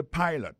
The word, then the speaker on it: pilot: Pilot là, c'est même mot pilote là, il dit E-A-D-L-I, écrit P-I-L-O-T. Alors, il s'est prononcé I dans le cas. Alors, et puis, dans le mot pilot là, tout, après, accent tombé sur le premier syllabe là.